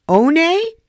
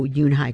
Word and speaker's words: Unhei: "One?" the girl asked, scrunching up her face. "One," some kids chanted. "No, no," Yoon Hae corrected. "It's spelled U-N-H-E-I. It's pronounced Yoon Hae."